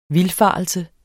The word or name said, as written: VILDFARELSENS